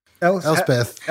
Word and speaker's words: Elspeth: Why did you throw away the second syllable like oh, that? that Elspeth.